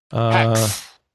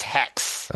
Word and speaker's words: HAEX: Hex. It's being pronounced Hex.